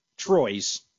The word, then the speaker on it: Troyes: I think it's pronounced the same way as they'd say three. Yeah. Troyes.